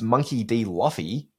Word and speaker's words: Monkey D. Luffy: it's Monkey D. Luffy.